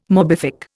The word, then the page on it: morbific: Indian